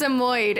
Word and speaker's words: Samoyed: It's a Samoyed. It's a Samoyed. At least that's how I've always pronounced it.